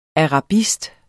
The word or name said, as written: arabist